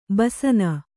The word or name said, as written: ಬಸನ